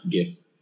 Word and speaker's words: GIF: It's pronounced GIF. – No way. – Yeah. – Really? Okay, read it to me. – Because the G in GIF is graphic. So it is a soft G.